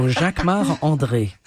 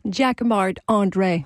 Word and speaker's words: Jacquemart-André: Jacquemart-André. You say it like André. – A Frenchman. – I'm French. So Jacquemart-André. – And say it so we can understand it. – Jacquemart-André.